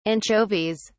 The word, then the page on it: anchovies: British English